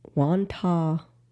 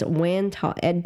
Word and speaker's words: Wantagh: over the Wantagh State Parkway when he struck an overpass in East Meadow and attempted to flee was allegedly drunk.